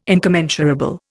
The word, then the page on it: incommensurable: Indian